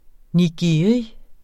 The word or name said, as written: nigiri